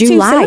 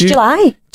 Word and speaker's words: July: It's like she's put July. – Two syllables. July. – July. – But it's July. – July. It is one syllable.